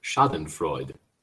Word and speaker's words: Schadenfreude: I asked you all before we got on, how do you say that word? Is it Schadenfreude? – The Google pronunciation is Schadenfreude.